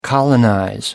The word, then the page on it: colonize: American English